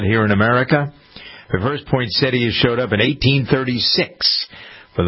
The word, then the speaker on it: poinsettia: Poinsettia, some people pronounce it, but it's poinsettia. That's the way the dictionary tells us. – There's a syllable in there. – Right, exactly. Yeah, there's a T-I-A in there, poinsettia.